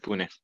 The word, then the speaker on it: Pune: So we have our top city in India, which we get the listening from, is Pune. Is that right? Am I saying it wrong? – Yeah, you got that right, Pune.